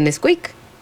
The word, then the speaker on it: Nesquik: Ellos son muy fans de Nesquik, que yo hace poco aprendí cómo se pronunciaba, porque se escribe N-S-Q-K. Se pronuncia Nesquik.